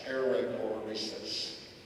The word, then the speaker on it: Perichoresis: P-E-R-I-C-H-O-R-E-S-I-S. Perichoresis.